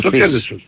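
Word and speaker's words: Fils: Τον Αρτούρ Φις. Τον Αρτούρ Φις. – Αυτό είναι, χωρίς το S δεν το προφέρουν οι Γάλλοι. Είναι λίγο παράξενο.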